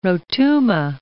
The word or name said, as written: Rotuma